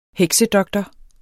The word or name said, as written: heksedoktor